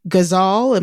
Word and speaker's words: Ghazal: And you mentioned Titus in your poem, Ghazal. Am I pronouncing it right? – Yes.